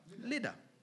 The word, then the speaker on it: Lydda: And since Lydda, I can't even pronounce that word. How do you pronounce it? Lydda. There you go.